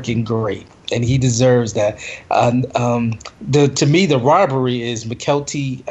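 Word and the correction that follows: Mykelti: Michael T.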